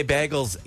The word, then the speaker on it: bagel: So bagel, whatever you say, rhymes with Lauren Daigle, in my opinion.